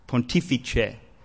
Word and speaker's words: Pontifice: How do you say that? Pontifice, he tells me.